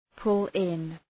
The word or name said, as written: pull in